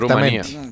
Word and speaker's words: Rumanía: Pues fácil, diga Rumanía y no molesto más. No, es que así no se dice. Sí, se puede decir Rumanía.